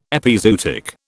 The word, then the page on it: epizootic: British English